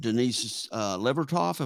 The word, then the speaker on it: Denise Levertov: And you borrowed Denise Levertov, am I saying her name right? – Mm-hmm.